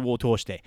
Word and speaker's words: を通して: But the を通して is a long o, and it's not elongated with the u sound, so if you're writing it, it's 通して, so two o's in a row, all right?